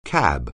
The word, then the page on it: CAB: anglais